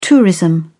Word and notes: tourism: British English

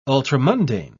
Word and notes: ultramundane: British English

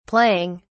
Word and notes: playing: British English

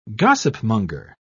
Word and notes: gossipmonger: British English

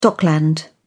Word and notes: dockland: British English

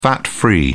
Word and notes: fat-free: British English

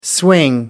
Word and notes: swing: American English

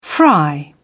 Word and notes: fry: British English

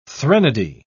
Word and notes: threnody: British English

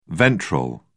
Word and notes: ventral: British English

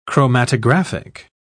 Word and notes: chromatographic: British English

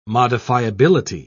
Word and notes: modifiability: British English

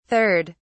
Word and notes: 3rd: British English

